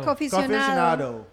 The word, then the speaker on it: Coffeecionado: Coffeecionado.